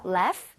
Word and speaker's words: laugh: Laugh. – Laugh? Okay. 자, 그러면 여기 써주세요. – Laugh at... – 스펠링을 제대로 만들어주세요. – Laugh... 그 같은데요? – GH는 F와 비슷하게 발음이 됩니다.